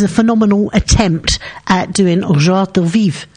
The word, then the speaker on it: joie de vivre: Tu es pleine de joie de vivre et j'adore vraiment t'entendre rire.